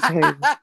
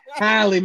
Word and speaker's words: Halle: I think I said, it's just like, I can see it like old girl being Haley, other girl being... – I know you did not pronounce Halle Berry's name as Haley. – Halle, my bad.